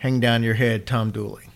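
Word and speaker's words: Dula: A man named Tom Dooley pronounced Dooley, but it's D-U-L-A is how it's spelled, but it's pronounced Dooley, was convicted and hanged for the crime.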